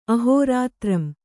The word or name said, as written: ಅಹೋರಾತ್ರಂ